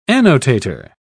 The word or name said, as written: annotator